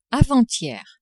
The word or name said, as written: avant-hier